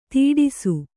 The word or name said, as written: ಧಾಡಸೀ